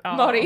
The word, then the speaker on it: Māori: Māori. No. – Māori, yeah.